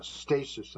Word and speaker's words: Stassis: It is dated June 3rd of 2026, being read in on June 3rd, and written by Christina Stasis, and I believe that's how her name is pronounced, S-T-A-S-S-I-S.